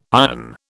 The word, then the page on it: iron: British English